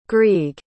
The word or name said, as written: grieg